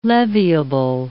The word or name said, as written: leviable